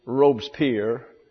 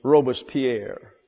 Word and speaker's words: Robespierre: The anglicized pronunciation is Robespierre. The French pronunciation is Robespierre. Take your pick.